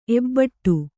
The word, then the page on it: ಎಬಡಿ: ebaḍi; kannada